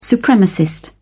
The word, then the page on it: supremacist: British English